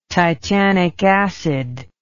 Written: titanic acid

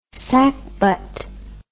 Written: sacbut

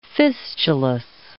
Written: fistulous

